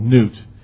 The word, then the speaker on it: Nut: And the Egyptians also worshipped a sky goddess called Nut, N-E-W-T. Actually, it's pronounced N-E-W-T. It's spelled N-U-T.